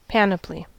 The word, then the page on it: panoply: American English